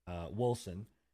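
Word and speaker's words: Wolcen: Yeah, so myself and Enigma and now Scruffy have been playing Wolsen or Wolken, however you want to pronounce it. But I think the proper term or proper saying for it is Wolsen.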